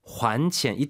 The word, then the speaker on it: 还: 그래서 欠债 환钱 이때 이제 그 환이 발음 유의하시기 바랍니다. 환钱 돈을 갚다죠.